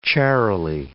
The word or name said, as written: charily